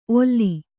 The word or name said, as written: ಓಲು